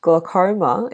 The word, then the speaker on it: glaucoma: Glaucoma is an interesting word to pronounce. It's spelled G-L-A-U-C-O-M-A. And you can pronounce it as either glaucoma or glaucoma. So the AU vowel pair produces a different sound, I think, between British and American accents.